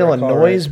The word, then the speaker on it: Illinois: Southern Illinois beat KU. Then I think the next year. – You don't pronounce the S.